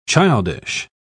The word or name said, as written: childish